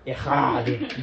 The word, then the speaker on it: echad: So that word "one" in the original Hebrew is the word "echad." And "echad." You got to get the phlegm in there to pronounce it properly.